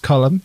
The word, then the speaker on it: Colm: It was, what Christmas movie did Colm Meaney, it's pronounced Colm, you'll all remember, play a pilot in? – I didn't think it was a Christmas movie.